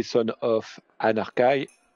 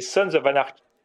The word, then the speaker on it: Sons of Anarchy: Euh, c'était pas bon, c'était bon ou pas C'est Sons of Anarchy.